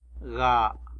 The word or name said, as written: ga